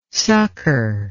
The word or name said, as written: succor